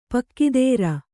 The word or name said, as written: ಪೊಗುತರ್